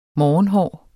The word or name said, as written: morgenhår